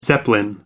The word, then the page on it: zeppelin: American English